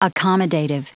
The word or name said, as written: accommodative